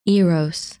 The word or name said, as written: Eros